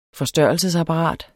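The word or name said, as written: FORSTØRRELSESAPPARAT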